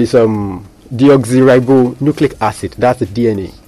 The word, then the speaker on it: deoxyribonucleic acid: Deoxyribonucleic acid. – Let me see if I can try. Deoxyribonucleic acid.